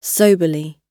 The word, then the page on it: soberly: British English